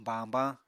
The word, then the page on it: bañbañ: Apatani